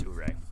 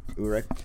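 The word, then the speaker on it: Ouré: Ouray, O-U-R-E. They pronounced it on the show, but I forgot how they actually pronounced it. – I think you got it right. I think it's Ouray. – Ouray.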